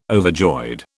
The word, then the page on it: overjoyed: British English